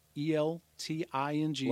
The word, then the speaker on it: Elting: Is C Elting, ELTING. – Like Elton John. – Like Elting John. – Not spelled the same.